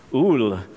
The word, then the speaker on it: Ole: And in that book, he tells the story of a Norwegian minister and author named, I don't know how to pronounce it, O-L-E, Ul, how do you pronounce it in Norwegian? Ul Hallesby.